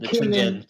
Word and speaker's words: Tianjin: We've got in the squad announced, we've got Kim Young-kwon of Guangzhou Evergrande, Jung Sung-hyun of Kashima Antlers, Kwon Kyung-won, Tanjin Kwanjian. I'll leave you for the Chinese pronunciation. Tianjin Chunjin. Tianjin.